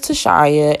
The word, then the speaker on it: Tashaya: Well, my name is Tashaya, and it rhymes with papaya, and I'm going to change the world through media.